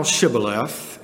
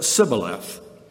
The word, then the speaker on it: Shibboleth: So they said unto them in verse 6, "Say now Shibboleth." And he said, "Sibboleth." And he could not frame to pronounce it right.